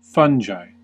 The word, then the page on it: fungi: /ˈfʌn.dʒaɪ/; /ˈfʌŋ.ɡaɪ/; /ˈfʌŋ.ɡiː/; /ˈfʌn.dʒiː/